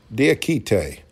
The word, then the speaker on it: Diakite: Yeah, but they signed Mamadi Diaki. How do you say his name? – Diakite. Yep.